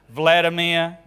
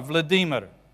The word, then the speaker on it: Vladimir: We get the emphasis on the wrong syllable and they don't sound right. So, like President Putin, what's his first name? Vladimir. No, Vladimir.